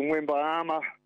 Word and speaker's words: Wembanyama: I mean, I was watching the basketball playoffs in Wembanyama. That's how you pronounce his game.